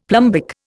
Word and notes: plumbic: Indian

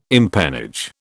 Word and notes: empennage: British English

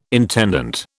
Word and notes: intendant: British English

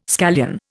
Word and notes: scallion: Indian

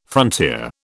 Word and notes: frontier: British English

